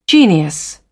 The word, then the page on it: genius: British English